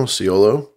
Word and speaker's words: Siolo: It's S-I-O-L-O. – S-I-O-L-O, Ciolo.